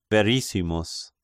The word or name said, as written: Verissimus